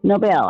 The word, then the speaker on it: Nobel: Now let me go ahead and ask you, you know, Nobel, I'm saying it correctly, right? – You are saying it correctly, yes ma'am.